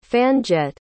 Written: fan-jet

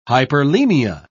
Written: hyperemia

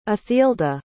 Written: athilda

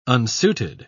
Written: unsuited

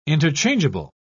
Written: interchangeable

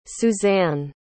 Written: suzanne

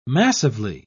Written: massively

